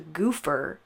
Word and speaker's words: Gopher: This gang, spelled G-O-P-H-E-R, was apparently pronounced goofer and was known as the Goofer Gang.